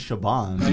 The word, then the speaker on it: Siobhan: Why are you saying Bon? – No, apparently it's Siobhan. – No, you're just, you're pronouncing the B.